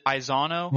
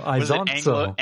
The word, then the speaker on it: Isonzo: Isonzo, because it's Italian, like Forza. – Grazie. – Yeah, exactly. That's yeah. – So Isonzo.